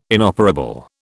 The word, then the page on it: inoperable: British English